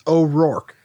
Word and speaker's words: O'Rourke: And I understand if no one can pronounce it because it's a super hard last name, but it's O'Rourke. It's just like fork with an R.